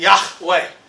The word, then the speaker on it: Yahweh: The name of God is Yahweh, or pronounced more specifically, Yahweh.